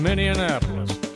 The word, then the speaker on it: Minneapolis: He's going to take us up to, and I've never heard Minneapolis pronounced like this, but maybe he's doing it right and I've done it wrong all my life. But check this out. Pretty early in the song, he pronounces Minneapolis in a little bit different way.